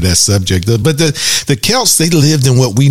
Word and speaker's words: Celts: The reason why I struggle with that because it's it's spelled C E L T S, but it's Celts, pronounced Celts.